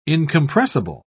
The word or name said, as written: incompressible